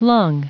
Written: lung

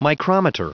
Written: micrometer

